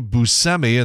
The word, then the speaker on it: Buscemi: Late night, Colbert has the cast of F9, including Vin Diesel and Helen Mirren, people like that, John Cena, Ludacris, James Corden, Steve Buscemi, and that is the correct pronunciation, and also Ed Sheeran.